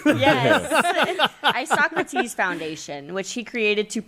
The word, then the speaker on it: Isocrates: Oh, I got the sound off. – Yeah. Oh, yeah. Ready? – Yeah. – Isocrates. – There you go.